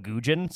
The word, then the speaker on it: Nguyen: so they just call him Gujin. So his name is Gujin. His name is spelled Nguyen, but it's Gujin.